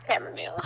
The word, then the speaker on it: chamomile: It starts cam. – What? Oh, chamomile? – Thank you. The internet is gaslighting us into thinking that it's chamomile, because that's how every pronunciation guide has it. – No, but it's not. – But it's not. It's definitely not. – It's Okay. – very much chamomile.